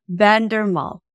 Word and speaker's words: Vandermolen: Our guest is Roy Vandermolen. I believe I've said your name right. Have I not, Roy? – Yeah, that's close. – I'm Irish and you're Dutch, so I mean, what can I say? – It's Vandermolen. – Vandermolen.